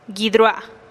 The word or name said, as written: guid rua'a